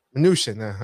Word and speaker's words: Mnuchin: Mnuchin.